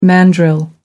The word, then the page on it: mandrill: American English